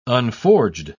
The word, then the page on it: unforged: British English